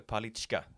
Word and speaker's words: Palicka: En viss Andreas Palicka då. Eller Palicka, som Johan väljer att uttala.